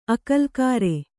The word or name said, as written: ಆಕಲಕರೆ